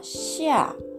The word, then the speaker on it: 下: その次の文字が、シア。これは下、上下の下という漢字を書きます。発音記号は XIA で、第 4 声上から下に急降下する音になります。